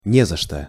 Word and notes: не за что: Russian

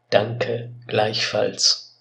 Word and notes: danke gleichfalls: German

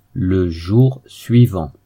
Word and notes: le jour suivant: French

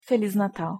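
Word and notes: feliz Natal: Portuguese

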